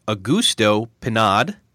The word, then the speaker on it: Augusto Pinaud: This week, I have an interview with Augusto Pinaud, and I hope I just pronounced that right.